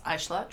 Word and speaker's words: Eichler: First of all, my last name, E-I-C-H-L-E-R, I pronounce Eichler, traditionally pronounced Eichler.